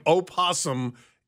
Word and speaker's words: opossum: Why would you put the O at the beginning of opossum if it's not to be pronounced? – Why is there an L in colonel?